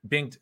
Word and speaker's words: Bengt: Oh, it's just a silent G, right? Bent. – Bent. – Oh, Bengt Viberg. – Bengt. All right, my bad. Yeah. Don't listen to me. – Bengt Viberg.